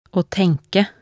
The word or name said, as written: å tenke